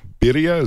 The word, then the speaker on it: birria: That the spices and the and is it birria? Is that how you pronounce it? – Birria. – Wow, yes.